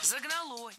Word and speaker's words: загнало: Загнало. Неверно. Правильный вариант – загнало. И здесь есть одна общая тенденция. В проблемных словах прошедшего времени среднего рода, то есть там, где мы сомневаемся, ударение на окончании не ставим категорически. Поэтому только загнало.